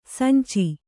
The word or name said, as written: ಸಂಚು